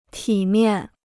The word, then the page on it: 体面: tǐ miàn